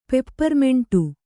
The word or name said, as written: ಪೆಪ್ಪರ್ಮಿಂಟು